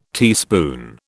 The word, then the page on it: teaspoon: British English